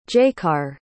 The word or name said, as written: jaecar